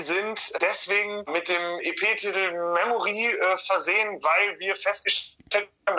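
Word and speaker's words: Memori: Oder spricht man die Memory aus? – Ja, machen ein paar Leute, aber wir sagen dazu Memory, weil es ist doch schon etwas angelehnt an dieses Kartenlegespiel Memory, ja.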